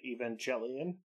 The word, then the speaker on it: Evangelion: It absolutely kills me that you stick with the way you pronounce it, given that the characters pronounce it Evangelion. The hard G.